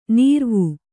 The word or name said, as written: ನೀರ್ವೂ